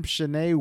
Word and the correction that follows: Shailene: Or Shailene Woodley as Mary Jane Watson.